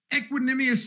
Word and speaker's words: Equanimeous: No Devontae Adams, no Alan Lazard, even Equanimeous St. Brown is out.